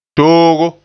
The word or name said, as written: toogo